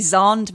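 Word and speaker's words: Xand: So my friend Zan van Tulleken, who is actually Zand van Tulleken, but I can't pronounce his name Zand because of my accent.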